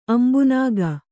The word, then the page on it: ಅಂಬುನಾಗ: ambunāga